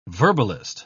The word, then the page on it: verbalist: British English